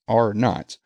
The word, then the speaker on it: R-naught: It's pronounced A-R-E-N-O-T, R-naught.